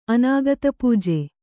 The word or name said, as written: ಅನಾಗತಪೂಜೆ